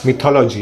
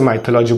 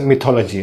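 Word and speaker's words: mythology: अगर आप मेरे पुराने वीडियो जाके देखोगे तो वहाँ पे मैंने इसे माइथोलॉजी बोला है, बट एक्चुअली इसका प्रोनाउंसिएशन मिथोलॉजी है।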